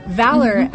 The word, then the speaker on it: Valor: Valor is spelled the American way, which is V-A-L-O-R. – And no, you do not pronounce it velour.